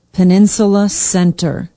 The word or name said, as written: peninsula center